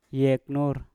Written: yeknur

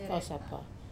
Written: kausapa

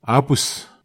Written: apus

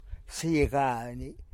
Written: sigani

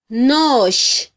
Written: nox

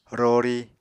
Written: rori

